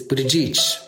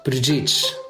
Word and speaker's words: Buttigieg: In Malta, they pronounce it, I was scolded many times, but I think I got it at the end. It is Buttigieg, Buttigieg, like that, Buttigieg. They kind of roll right over the first two syllables and then draw out the third, like each.